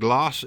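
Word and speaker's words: glace: Glass is, of course, ice.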